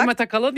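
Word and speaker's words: Demet Akalın: Und zwar Demet Akalin. – Ja. – Habe ich das so richtig gesagt? – Ja, Demet Akalin, ja, super.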